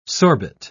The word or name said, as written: sorbet